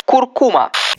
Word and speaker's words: куркума: Куркума. Верное произношение этого слова встречается редко. Мы чаще всего говорим куркума, однако правильно куркума.